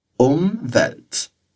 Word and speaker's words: Umwelt: Ah, e repara só que esse do começo é com M e não com N, tá ok? Então, a gente tem que fechar os lábios para pronunciar. Umwelt.